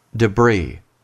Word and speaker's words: debris: 단수일 때는 debris라고 읽고 복수일 때는 똑같이 스펠링을 쓰지만 debris라고 읽습니다.